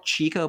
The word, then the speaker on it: Chico: It's, it's spelled Chico, but it's pronounced Chico. We'll get into why